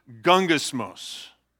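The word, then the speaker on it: gungismos (γογγυσμός): But the word for grumbling here is pronounced gungismos. Doesn't that sound like grumbling?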